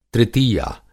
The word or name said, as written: तृतीया